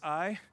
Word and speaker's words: Ai: A-I, it's pronounced Ai.